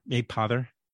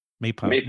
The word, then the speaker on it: Mapother: Mapother. – Mapother. Mapother. Mapother. Mapother. Yeah, Mapother. It's a tough one. You hit that first it's syllable. Not, there you go.